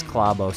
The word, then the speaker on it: Klæbo: And it's Klæbo. Yeah, no, in Norway, they say Klæbo. So in America, everyone says Klæbo. But I asked some Norwegians, how do you pronounce his last name? It is Klæbo.